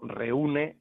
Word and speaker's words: reúne: Entonces, Ceuta no lleva tilde, porque el golpe de voz está en la abierta, en la E, pero reúne lleva tilde porque el golpe de voz está en la U, ¿de acuerdo? – Perfecto.